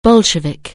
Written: Bolshevik

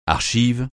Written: archives

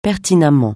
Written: pertinemment

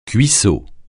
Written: cuissot

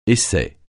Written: essai

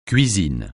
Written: cuisine

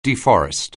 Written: deforest

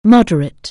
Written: moderate

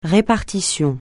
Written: répartition

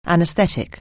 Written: anesthetic

